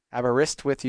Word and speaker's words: Aberystwyth: Aberystwyth University.